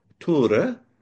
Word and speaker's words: Tor: He said I can call him Tor, but it's it is pronounced Ture.